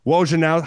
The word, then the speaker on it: Wojnarowski: Adrian Wojnarowski. How do you say Woj's last name? I've not said Woj's last name. – Wojnarowski.